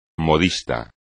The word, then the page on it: modista: Spanish